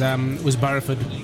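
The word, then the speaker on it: Barrowford: Well, I think the school that we focused on mainly was Barraford, I should say. That's how they like to pronounce it up there.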